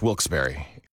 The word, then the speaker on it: Wilkes-Barre: The only way to pronounce Wilkes-Barre.